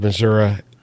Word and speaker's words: Missouri: It sort of is, and actually not everyone in the state of Missouri pronounces it Missouri. That's more of a central and south Missouri pronunciation.